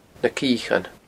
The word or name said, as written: Na Ciochan